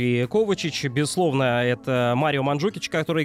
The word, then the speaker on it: Ковачич: Естественно, Лука Модрич, Иван Ракитич, как и известны хорватские имена, э, вернее, имена и фамилии, да, здесь, как правило, ударение на первый слог, поэтому не Ракитич, не Ковачич, там, не Брозович или там э, Манжукич, а Ракитич, Кова... Ковачич, Брозович и Манжукич.